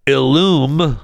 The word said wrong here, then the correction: Ellume: I guess that's how you say it, Ellume, is recalling its at-home COVID-19 test over the potential of false positive results.